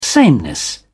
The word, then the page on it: sameness: British English